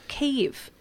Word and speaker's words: Kyiv: And by the way, Kyiv, it's not spelt K-I-E. Anymore. It is actually K-Y-I-V. That is the correct way to pronounce it and to spell it.